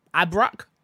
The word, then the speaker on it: Abrak: Interactive director, I'm going to butcher this name, Hakan Arabic, Ab- Abrak, you nailed it.